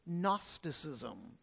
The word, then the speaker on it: Gnosticism: We spell it Gnosticism with a G, but it's pronounced without the G, really. In English, the term is Gnosticism.